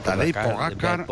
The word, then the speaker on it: Tadej Pogačar: Sí, Tadej Pogačar, Pogačar.